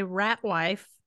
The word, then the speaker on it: Ratwyfe: So there's this really adorable song by Ratwife. – Ratwife. – Which is spelled interesting. – Ratwyfe.